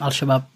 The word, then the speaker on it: Al-Shabaab: Yep, Al-Shabaab.